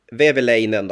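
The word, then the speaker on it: Vehviläinen: Eh, som målvakt så tog jag Veini Veveleinen då. Var det rätt uttal Abris eller? Ja det lät jättebra.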